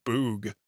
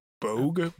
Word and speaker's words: bogue: No, it is a bug-eye fish called a boog or bogue. B-O-G-U-E. I said it right the first time.